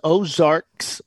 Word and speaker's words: Ozarks: It was Ozarks, plural, International Raceway. Ozarks International Raceway.